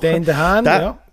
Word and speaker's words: Dane DeHaan: Und zwar geht es darum, dass der Lockhart, nämlich gespielt von Dane DeHaan. Ich hoffe, das wieder richtig ausgesprochen. – Dane DeHaan, genau, der muss sein CEO abholen.